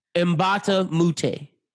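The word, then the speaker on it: Mbah a Moute: How you say his name? Luke? – Mbamba Mute.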